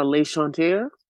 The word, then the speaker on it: L'Enchanteur: So can first of all, you tell us your preferred pronunciation of this beautiful line? – We are L'Enchanteur.